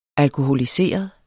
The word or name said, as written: alkoholiseret